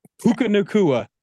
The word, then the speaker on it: Puka Nacua: Puka Nacua. – Puka Nacua making waves on the NFL gridiron.